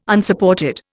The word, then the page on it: Unsupported: Indian